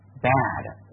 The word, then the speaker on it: bed: 好，那么大家看一下，bed 这个词的话，读音读注意不要读成 by 的哈。 bed 口型的话，适中就好了，自然一点，不要把语音往后吞。如果把音往后吞，那就成 by 的了哈。也不要把口型往两边张，读成 bad，那是不坏那个词哈。 bed 就好了，bed 很自然的是吧？正常口型，不用收也不用放，OK bed 是吧？